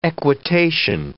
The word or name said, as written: equitation